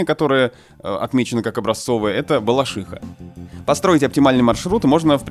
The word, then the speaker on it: Балашиха: И словари для работников СМИ, словари имен собственных говорят о том, что правильно балашиха. То есть то, как произносят местные.